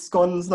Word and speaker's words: scones: It is scones, not scones.